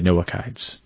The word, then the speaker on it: Noachides: Now, there are different ways to pronounce this. Noachides, Noachites, Noachides, Noachides. I'm going to say Noachides.